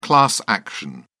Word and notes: class action: British English

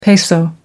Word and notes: peso: American English

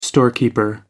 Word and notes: storekeeper: American English

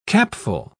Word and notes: capful: British English

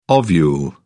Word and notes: ovule: British English

